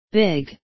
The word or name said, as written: big